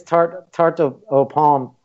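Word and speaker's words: tarte aux pommes: And I would say this tarte au pomme. How do you pronounce it again? – Tarte au pomme.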